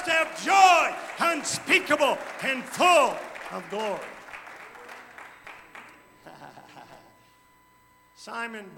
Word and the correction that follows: Simone: Biles, a young Olympian. Simone, you're right, it is Simone. I wasn't even looking at it and said it wrong.